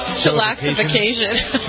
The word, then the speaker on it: Chillaxification: His 22 stadium dates on the Chillification. I thought I pronounced it right the first time. – Chillaxification. – Chillaxification. Okay. – I'd just like to hear you say it. That's more fun. – Chillaxification, yes.